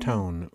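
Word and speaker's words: Towne: Town, oui. – On prononce pas le. – Non.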